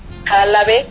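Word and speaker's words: Halabe: Sí, sí, la página está a mi nombre, Elvira Jalabe, que en lugar de H, en lugar de J es H.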